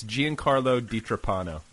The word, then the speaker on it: Giancarlo DiTrapano: It's Giancarlo DiTrapano.